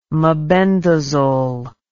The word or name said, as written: mebendazole